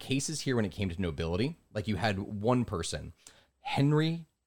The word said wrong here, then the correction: Henri: de Moldenville, or Henry de Moldenville, because I know it's French. I know that Henri. – I mispronounced Henri.